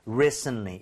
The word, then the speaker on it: recently: 자, recently는요, 발음을 할때 T 사운드가 거의 안 난다고 봐야 됩니다. 오케이? 그래서 recently. 이런 식으로 앞쪽에 좀 강세를 실어서 발음을 해주세요. recently.